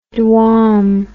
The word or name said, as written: dwam